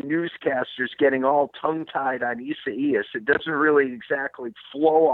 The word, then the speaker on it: Isaias: Dan, I heard that on a broadcast this weekend, so that's a fairly easy pronunciation for Isaias. If you say Buenos dias first, then you can, Isaias kind of just flows off your tongue.